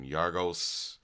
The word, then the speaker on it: Yorgos: I mean, like, if you, Dogtooth director, I cannot pronounce this guy's name, Yorgos.